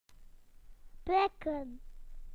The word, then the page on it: to prick: British English